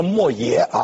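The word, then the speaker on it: 莫邪: 最有名的墨邪，或者叫墨邪，这个基本念成墨邪啊，这个人他是春秋时期的人名，今天这个字啊已经不再是人名了，还被用来比喻非常锋利精美的宝剑。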